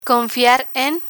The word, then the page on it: confiar en: Spanish